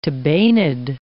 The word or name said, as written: tabanid